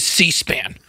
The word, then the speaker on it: C-SPAN: Listen, when you say Suspan, it is like nails on a chalkboard. It is C-SPAN. – What? – You're saying it wrong.